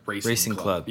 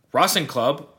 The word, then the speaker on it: Racing Club: Which literally just looks like Racing Club. – Yeah, but pronounced Racing Club.